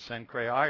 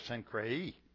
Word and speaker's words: Cenchreae: And there's two different pronunciations, Cenchreae or Cenchreae.